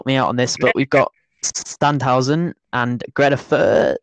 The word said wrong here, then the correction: Greuther Fürth: It's Sandhausen and Greuther Fürth.